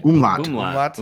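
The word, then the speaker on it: Umlaut: Like an umulet over it. Is that how you say it? Umulet? Umulat? Umlai? – Umlat. – Umlat.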